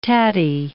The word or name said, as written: tattie